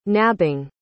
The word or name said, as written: nabbing